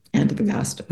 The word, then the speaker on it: Antofagasta: Antofagasta.